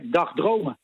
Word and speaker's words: dagdromen: Nee, jij zei daar straks, in Assen zijn ze aan het dagdromen van een tweede Formule 1 race in Nederland. En ik denk dat je de klemtoon net verkeerd legt. Het is meer dagdromen.